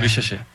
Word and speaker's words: Risacher: A pronúncia, é... – Risacher. – É o Zachary Risacher.